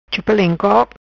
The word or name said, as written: Chupa Leng Kok